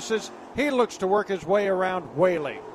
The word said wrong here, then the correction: Whaley: Yes, as you saw, I believe it's Wally is how you pronounce it, actually, so that's my apologies, folks.